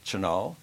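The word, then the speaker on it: Tionól: But T-I-O-N-O-L is Chenal. That's how I pronounce it.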